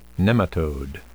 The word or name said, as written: nematode